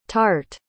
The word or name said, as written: tarte